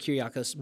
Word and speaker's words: Kyriakos: And so I believe this is the Greek word Kyriakos, is that how you say it? – Yeah, Kyrios. Yeah, you can say it Kyriakos.